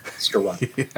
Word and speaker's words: Skowronek: Skowronek. – It rhymes with ironic. Skowronek.